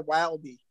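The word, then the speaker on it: Wylde: Yeah, but you don't pronounce the E. – No, I'm going to call her Wilde.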